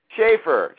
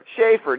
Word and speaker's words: Schaffer: S-C-H-A-F-F-E-R. Schaefer. – How do you say it? How do you say it? – Schaefer. Schaefer. Neil Schaefer.